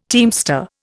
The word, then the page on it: teamster: Indian